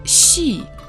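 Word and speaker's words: 戏: Слово «си» произносится в четвертом тоне.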